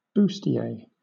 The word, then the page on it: bustier: British English